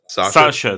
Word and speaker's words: Sascha: Am I pronouncing that right? – Sasha, that's correct, yeah.